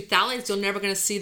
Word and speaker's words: phthalates: Okay, well, I'm glad you said phthalates,